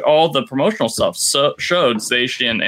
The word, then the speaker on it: Zacian: And I found out on that day that what we've been calling Zacian is actually pronounced Zacian. That CI is apparently doing some SHs work there.